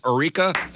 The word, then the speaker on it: Eureka: Eureka? – That's it.